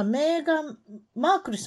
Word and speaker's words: メーガン・マークル: はい、それで、誰と結婚したかっていうのは、メーガン・マークルさんっていうんですか読み方としては。そうですね。